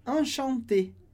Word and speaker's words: enchanté: A pronúncia fica exatamente a mesma, enchanté, enchanté. Porque esse E sem acento do final, a gente corta, a gente não pronuncia.